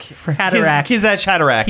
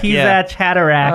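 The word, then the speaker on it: Kwisatz Haderach: That only the Kisatch Haverdack can use. I can't remember how you pronounce that. I probably butchered it so bad. – Oh. – Haderach. Kisatch Haderach. – Kisatch Haderach.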